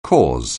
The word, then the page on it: cause: anglais